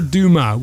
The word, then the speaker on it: Dumas: It is spelled D-U-M-A-S, Dumas, but I think you don't pronounce the S because it's French.